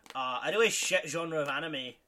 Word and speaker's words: anime: Anime? – I think it's pronounced Enema. – What? – Enema. – Enema, yeah. Anime.